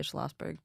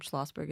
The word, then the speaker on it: Schlosberg: I'm the only Deia Schlosberg, pretty much. – Wow. – So I'm findable. My last name, Schlosberg, is spelled with one S.